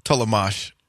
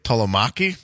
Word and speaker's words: Tollemache: T-O-L-L-E-M-A-C-H-E. Tolomesh is how it's pronounced. When I first saw it, I was like, Tolomaki? No.